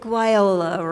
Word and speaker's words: Wyola: We'd coasted back roads, looped Lake Wyola rhymes with cola, which I'd chant as we rolled for lack of much to think about.